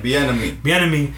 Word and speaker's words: Bieniemy: Yeah, this guy's an amazing Eric Bieniemy, whatever I even say his name. – Bieniemy, he's an amazing assistant coach, offensive coordinator.